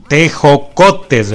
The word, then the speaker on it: tejocotes: Pues mira, esta receta requiere de algunos ingredientes tradicionales como la canela, los tecojotes. si lo, lo dije bien, te, eh, tejo, teco, tejocotes, ¿verdad? Tejocotes. Está perfecto esto, ¿verdad?